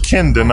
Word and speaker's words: Kyndon: You're the first Kendon I've ever met. His name is spelled K-Y-N-D-O-N.